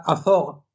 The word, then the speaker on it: Azog: Pero, cuidado, nos reímos, esto parece una tontería, pero es sumamente importante este hecho, el hecho de que exista la palabra Azog, azog, correctamente pronunciado, eh, escrito en la frente del enano Throg.